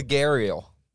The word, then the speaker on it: Gharial: This one's called the Garial. It's spelled G-H-A-R-I-A-L. – Garial.